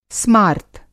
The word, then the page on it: स्मार्त: Hindi